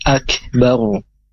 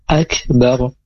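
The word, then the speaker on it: akbaru (أَكْبَرُ): Akbar, ro-nya disukunkan, dengan hukumnya adalah ro dibaca tafkhim, karena ketika ro itu sukun, kemudian huruf sebelum ro harokat fathah atau dhammah, maka dibaca tebal. Jadi bacanya akbaru, bukan akbar. Kalau akbar itu tipis dia dibaca huruf ro-nya, tapi ini dibaca tebal.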